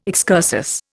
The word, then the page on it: excursus: Indian